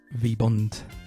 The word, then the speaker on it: VBND: I think it's pronounced V-Bond.